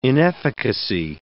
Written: inefficacy

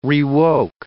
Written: rewoke

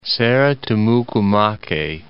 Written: Serra Tumucumaque

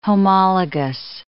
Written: homologous

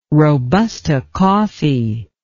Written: robusta coffee

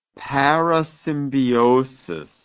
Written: parasymbiosis

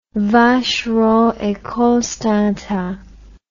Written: vacheron et constantin

